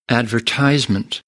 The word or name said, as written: advertisement